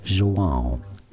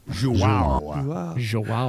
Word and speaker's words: João: João. – João.